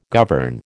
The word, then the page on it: govern: American English